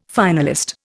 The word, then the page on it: finalist: Indian